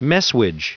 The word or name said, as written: messuage